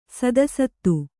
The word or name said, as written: ಸದಸತ್ತು